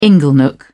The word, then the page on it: inglenook: British English